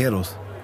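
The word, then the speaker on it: Héroes: Nee, Eros. Ja, das H spricht man nicht.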